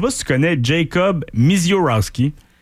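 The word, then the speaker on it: Misiorowski: Un autre nom qui est dur à prononcer. – Je l'ai mieux dit que je crois. – Ouais, tu l'as mieux dit, ouais. – Mizorowski. Ça se dit bien.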